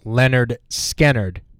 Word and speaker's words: Lynyrd Skynyrd: One of my favorite Lynyrd Skynyrd songs and their most popular, Simple Man, was released on their 1973 debut album, pronounced Lynyrd Skynyrd.